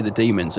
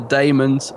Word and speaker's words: Daemons: But we were talking last week about how you say it, because I always say the demons, and I'm wrong, because the doctor clearly says it's the daemons from Deimos. – He does, doesn't he? – Yeah, so it is the daemons.